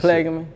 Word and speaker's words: Plaquemine: What is it, Plaquemine? How do you say that Plaquemine.